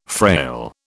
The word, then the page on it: frail: British English